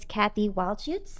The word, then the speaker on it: Cathy Wildshutz: My guest is Cathy Wildshutz.